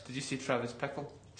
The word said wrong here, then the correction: Bickle: Travis Bickle, not Travis Pickle.